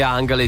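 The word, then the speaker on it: Angèle: Com'è che la pronunci? Angele? – Non lo stavo chiedendo. – Direi Angele, sì.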